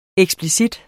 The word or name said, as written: eksplicit